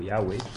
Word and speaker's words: Yahweh: Hein, nous, on le prononce plutôt Yahweh.